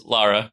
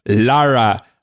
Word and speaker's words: Lara: Am I pronounced Lara? – Yes, Lara. – Lara.